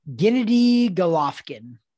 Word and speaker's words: Gennady Golovkin: Gennady Golovkin.